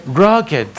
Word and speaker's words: rugged: How do you pronounce that word? Rugged. Forgive me. The Old Rugged Cross.